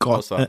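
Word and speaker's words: Xhosa: Äh, ich ich habe vor kurzem die Aussprache von dieser Sprache gelernt. Das ja. ist Gossa.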